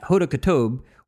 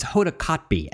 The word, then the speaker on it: Hoda Kotb: They just announced that Hoda Kotb, or it's Hoda Kotb, actually. I always mispronounce her name.